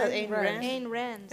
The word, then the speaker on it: Ayn Rand: Hoe spreek je haar naam nou fatsoenlijk uit? Het is Rand. Ik zeg Ayn Rand. Ayn Rand.